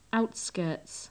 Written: outskirts

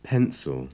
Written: pencil

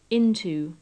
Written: into

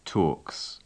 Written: talks

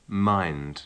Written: mind

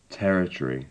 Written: territory

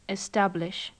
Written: establish

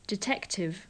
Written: detective

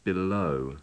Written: below

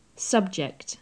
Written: subject

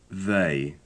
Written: they

